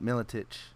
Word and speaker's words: Miletich: Was it A, Pat Miletich? Tech or Tech? – Pat Miletich.